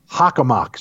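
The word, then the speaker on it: Hockomock: There are also stories of Native American curses over Hamakonkwunk Swamp. Tim, Hamakonkwunk Swamp. That's exactly how it's pronounced? – Hockamock Swamp. I don't know why my mouth refused to work with me there, but it's Hockamock Swamp.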